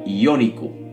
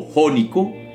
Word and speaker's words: Jónico: Y a partir de allí llamaron el mar que rodea Grecia el mar Iónico, o Jónico, como lo pronunciamos nosotros.